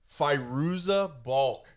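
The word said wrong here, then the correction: Fairuza Balk: Faruza Balk.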